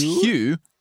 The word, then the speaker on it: Huw: No, it's Hugh is how you say it.